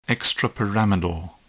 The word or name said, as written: extrapyramidal